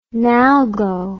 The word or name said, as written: NALGO